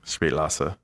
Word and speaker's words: Spätlese: Anyways, S-P-A-T-E-L-E-S-E, Spätlese is how you say Spätlese Riesling.